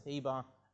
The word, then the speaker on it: Eber: If you remember, I took you to the New Testament where Eber is pronounced Heber, and that's where we get the term the Hebrews from, okay?